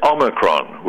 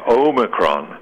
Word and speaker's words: Omicron: It's Omicron with a short O. It's not what TV and radio announcers keep hearing, and reporters too. They keep saying Omicron. That long O sound, O, is represented by an entirely different Greek letter, the probably much more familiar omega, which comes at the end of the Greek alphabet.